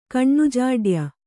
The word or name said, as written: ಕಣ್ಣುಜಾಡ್ಯ